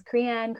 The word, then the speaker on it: Crean: Google Amanda Crean, and nobody, I get my name pronounced Crean, but it's C-R-E-A-N, not like cream, creamer, but yeah.